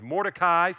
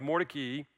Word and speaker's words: Mordecai: His name is Mordecai. Some I've heard it pronounced Mordecai.